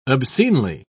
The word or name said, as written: obscenely